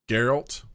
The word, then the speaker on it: Geralt: Or how do you say it? – Geralt. – Geralt. – Mm-hmm.